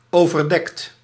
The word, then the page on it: couvert: France (Paris); [ku.vɛʁ]